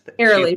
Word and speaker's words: Arely: We have her manager, Ariel Bratton. I'm hoping I'm pronouncing that correct. – Airlie Bratton. – Airlie. I'm messing up locations. I'm messing up names today.